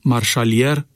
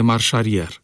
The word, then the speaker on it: marșarier: Vă vorbeam și cu altă ocazie despre substantivul jantă, pe care majoritatea șoferilor îl pronunță geantă, tot așa cum le vine mai ușor să pronunțe marșalier în loc de marșarier.